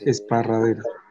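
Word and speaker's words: Esparreguera: Vivo cerca de Barcelona, en Cataluña, en España, y estoy en un pueblo que se llama Esparraguera, que siempre es difícil de pronunciar porque tiene muchas R's. – Esparraguera. – Esparraguera, sí, sí.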